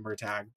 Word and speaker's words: Murtagh: He did a little countdown over the past week or so and revealed that the new book, Murtaugh, I know he pronounced it Murtagh, but it's Murtaugh.